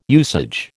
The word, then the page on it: usage: American English